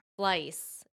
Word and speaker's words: Fleiss: How do you say her last name? Heidi Fleiss.